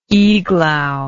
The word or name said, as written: iglau